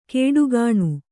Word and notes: ಕೇಡುಗಾಣು: kēḍugāṇu

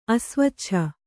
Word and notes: ಅಸ್ವಚ್ಛ: Kannada

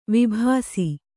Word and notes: ವಿಭಾಸಿ: vibhāsi

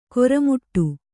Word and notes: ಕಾರ್ಮೋಡ: Kannada

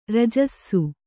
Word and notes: ರಾಜಸ: rājasa

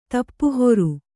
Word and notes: ತಾಪಹರ: tāpa hara

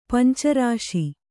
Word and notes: ಪಂಚರಸ: panca rasa

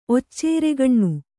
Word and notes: ಒಚ್ಚೇರೆಗಣ್ಣು: occeregaṇṇu

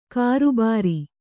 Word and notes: ಕರ್ಬುರ: karbura

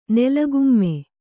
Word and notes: ನೆಲಗುಮ್ಮ: Kannada

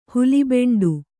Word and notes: ಹುಲಿಬೆಂಡು: huli beṇḍu

